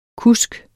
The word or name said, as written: kusk